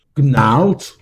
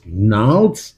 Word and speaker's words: Gnarls: Es ist Gnarls, Gnarls, Gnarls. Das G spricht man nicht.